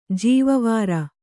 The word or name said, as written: ಜೀವವಾರ